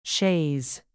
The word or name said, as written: chaise